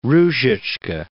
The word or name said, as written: Ruzicka